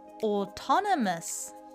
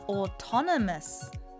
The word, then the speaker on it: autonomous: Autonomous Autonomous Sounds great! 発音は「と」のところを強調するとスムーズに言えるかもしれません。